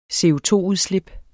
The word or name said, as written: CO₂-udslip